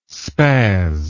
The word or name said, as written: spaz